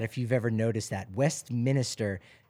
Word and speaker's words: Westminster: It's Westminster, not Westminster. It's not Minister. – Oh, no. – It's Westminster.